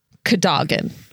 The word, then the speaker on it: Cadogan: And so they spell this Welsh name C-A-D-W-G-A-N. And it's pronounced Cadogan, meaning glory in battle.